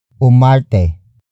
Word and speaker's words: umarte: For example, napakahusay umarte ni Nora. Napakahusay umarte ni Nora. Napakahusay umarte ni Nora. It means, Nora performs excellently. This sentence refers to Nora as an actor. Word for word, it's napakahusay umarte ni Nora.